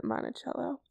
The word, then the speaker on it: Monticello: I think they pronounce it Monticello, though, but it's spelled the same.